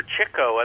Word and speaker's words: Chico: However, Chico, and that's the correct pronunciation of his name because he chased chicks.